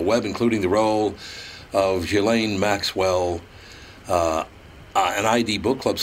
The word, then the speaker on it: Ghislaine: Television says a million different things, but it's pronounced Ghislaine Maxwell.